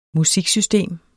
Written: musiksystem

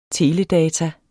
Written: teledata

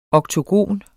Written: oktogon

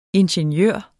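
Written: INGENIØRER

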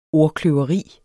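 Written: ordkløveri

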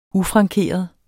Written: ufrankeret

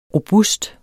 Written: robust

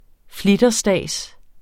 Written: flitterstads

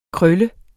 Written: krøl

